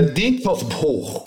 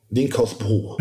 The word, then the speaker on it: Dinkelsbühl: O Summer Breeze alemão decidiu remover o Armored Dawn de sua próxima edição programada para agosto em Dinkelsbühl, Baviera. Não falei errado, foda-se. Após críticas... – Não, na verdade é Dinkelsbühl. – Tá. – É Dinkelsbühl.